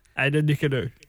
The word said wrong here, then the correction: Ada Nicodemu: I reckon you're missing a couple of syllables, mate. – What is it, Maddie? – Ada Nicodemu.